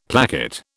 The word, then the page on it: placket: British English